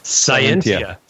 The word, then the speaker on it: Scientia: Anybody want to pronounce that for me? Scientia? Scientia? – Scientia. – Scientia. Yeah. Ooh, it's kind of like sentient, but not.